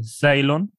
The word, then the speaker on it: Zeilon: Z-E-I-L-O-N. Hur uttalar man det? Ceylon? Ja.